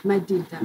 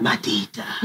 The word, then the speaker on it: Madida: Madida. – Madida.